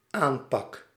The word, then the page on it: aanpak: /ˈaːnpɑk/